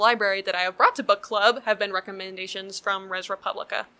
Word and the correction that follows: Res Publica: Res Publica, I keep mispronouncing it.